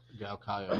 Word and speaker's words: Galkayo: Musa was born in Galkayo. Oh, dude, I'm not even pronouncing any of this shit, so I'm going to look terrible. – Galkayo, that's right.